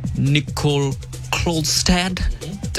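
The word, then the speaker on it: Nicoll-Klokstad: Stand in winger as well, Chance Nicol Klodstad. Did I pronounce that right? – Yep.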